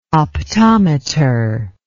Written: optometer

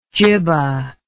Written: jibba

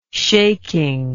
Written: shaking

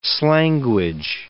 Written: slanguage

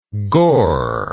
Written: gor

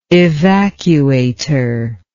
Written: evacuator

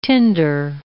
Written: tinder

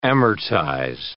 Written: amortize

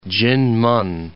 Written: Jinmen

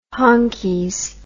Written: honkies

